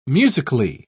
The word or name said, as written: musically